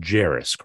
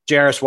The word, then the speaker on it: Jarace: it's pronounced Jarace, correct? Jarace Walker, yep.